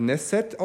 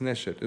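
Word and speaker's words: Neşet: Das gehört natürlich dazu, dass so jemand wie mein Neshet, den schreibt man halt auf Deutsch mit S, dann wird er halt Neset ausgesprochen und ich weiß halt, nee, das ist Neshet.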